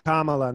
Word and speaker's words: Kamala: actually it's i think kamala not come election i think it's kamala no, right her name That's, you, you actually said it right.